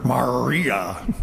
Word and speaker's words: Maria: In Latin and Spanish, the R in her name isn't a hard R or a pirate's R, Maria. But rather, it's an R that is pronounced with almost a flip of the tongue. And so that R ends up sounding almost like an L.